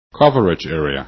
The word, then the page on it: coverage: english